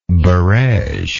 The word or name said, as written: barege